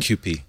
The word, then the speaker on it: AMQP: is it's in contrast to AMPQ, however you pronounce that? – AMQP, yeah. – Okay, all right. AMQP, I had the letters in the wrong way order, right?